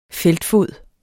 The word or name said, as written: feltfod